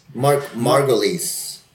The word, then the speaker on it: Margolis: Is it Margolis? Margolis? – Margolis. – Margolis? Mark, yes. Margolis. Margolis. Thank you.